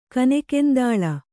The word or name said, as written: ಕನೆಕೆಂದಾಳ